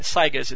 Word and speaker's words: Saiga: I've heard it pronounced different ways. That's Chip's version of pronouncing it, Saigas. It's spelled S-A-I-G-A if you want to Google it online.